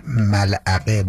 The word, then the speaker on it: ملعقه: ملعقه با عین و قاف، ملعقه. به خاطر یکم تلفظش سخت، ملعقه یه مقدارم آدم یه جوری میشه میگه، انگار داره عربی میگه، ملعقه اینجوری میشه، کلمه ناجوریه برای گفتم.